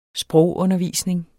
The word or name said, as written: sprogundervisning